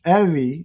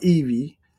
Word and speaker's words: Evie: It's Evie, as in Chevy, not Evie.